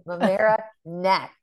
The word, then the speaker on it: Mamaroneck: But I was like, Dad, it's Mamaroneck. – Yes. – It's like he was proud to say it wrong.